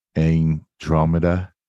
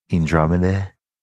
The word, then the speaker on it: Andromeda: The constellation name Andromeda is pronounced Andromeda. Andromeda.